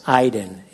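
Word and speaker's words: Aiden (עדן): So let's get back to Eden, or as it is pronounced, Aiden, A-Y-D-E-N. Aiden is the spelling Eden.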